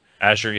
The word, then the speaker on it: Azure: Yeah, it was the first game that completely made use of the Microsoft Azure. Is that how you say it? – Azure.